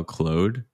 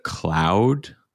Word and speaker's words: Claude: And by the way, in a previous video, everyone made fun of me that I call Claude instead of Cloud. But I speak French. And, you know, think about Jean-Claude Van Damme. I wouldn't call it Jean-Claude Van Damme. That's not how you say it in French.